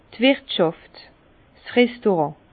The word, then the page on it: d' Wirtschàft, 's Restaurant: Alsatian; Bas-Rhin; Herrlisheim